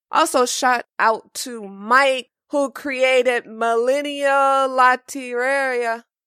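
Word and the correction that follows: Lotería: Okay, Renee, help me out with the pronunciation, because this is Mexican bingo. Did I pronounce it right? Latiria? – Mexican bingo. – Loteria. – Yeah.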